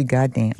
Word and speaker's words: Goddam: Goddamn. – That's the name of it's the name of a town, actually. – Mm. – It's spelled Goddam. It sounds like the cuss word, but it's actually about a town in Mississippi.